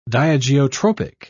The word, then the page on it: diageotropic: British English